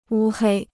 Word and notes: 乌黑: wū hēi

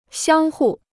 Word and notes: 相互: xiāng hù

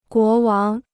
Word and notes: 国王: guó wáng

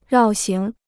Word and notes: 绕行: rào xíng

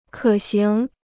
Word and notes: 可行: kě xíng

